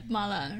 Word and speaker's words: mala: Finally, you finish it with ground Sichuan peppercorns, which give Mapo Tofu that signature mala. Is that how you say it? – Yeah, mala.